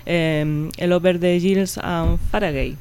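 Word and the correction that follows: Far Away: Over the Hills and Faraway.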